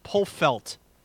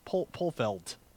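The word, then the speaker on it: Polfeldt: David Polfelt. He felt the poll. – You say Polfelt.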